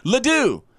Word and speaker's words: Ledoux: Actually, you are incorrect. Ledoux. L-E-D-O-U-X, Ledoux. – Oh, like how the French spell it.